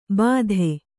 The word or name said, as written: ಭಾತಿ